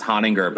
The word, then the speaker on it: Honinger: It's pronounced Honinger, but people say ho, and then they say another word, and it's just like, it's all inappropriate things with the name.